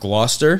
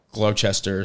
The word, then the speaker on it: Gloucester: I think it's pronounced Gloucester. – Gloucester. – Yeah. I always thought, growing up, I thought it was like Gloucester or something because I'm an idiot.